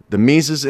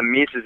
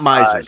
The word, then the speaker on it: Mises: Is it the Mises Institute in Auburn? Is that, I pronounce that correctly? – You nailed it, dude. Yeah, you're one of the first people to ever say it properly. Yeah, it's the Mises Institute. – Not Mises. Not Mises. – No, everyone says that.